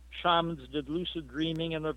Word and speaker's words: shamans: But the thing that most people don't realize is that shamans from around, or shamans, either pronunciation is correct, shamans from around the world often engage in what we would call lucid dreaming.